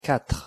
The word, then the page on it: quatre: French